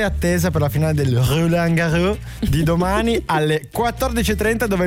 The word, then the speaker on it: Roland Garros: Roland Garros.